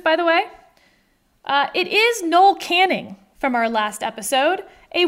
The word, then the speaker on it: Noel Canning: Who's on strike, and they pronounced it Noel Canning. So I feel like that whole debate's put to rest, and I felt really good, and I slept well that night, knowing how to pronounce Noel Canning.